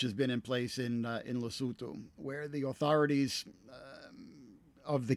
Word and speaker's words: Lesotho: Lesser noted, because it's a much smaller country, but South Africa's landlocked neighbor, Lesotho, or as I believe it is actually pronounced, Lesotho, issued its first licenses for legal cultivation of medical marijuana for export to the global market in places like Canada.